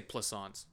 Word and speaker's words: Plaisance: And I was like, just say plaisance, like croissants.